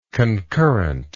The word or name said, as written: concurrently